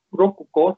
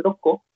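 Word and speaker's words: roco (六個): Entonces te carga la K en 1, Ikko, en 6, Rokko, no se dice Roku, rokuko, se dice roco.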